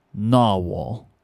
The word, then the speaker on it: Nahvalur: So the way you pronounce that word is Narwhal.